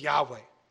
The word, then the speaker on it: Yahweh: And all these references, by the way, state that the name is either or likely or is pronounced as Yahweh. Does not say YHWH. This is Yahweh.